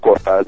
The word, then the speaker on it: Cortaz: pronúncia correta é Milen Cortais, é isso? Com um H é, Meu nome é Milen Avanti, tem o italiano no meio e o Cortais no final.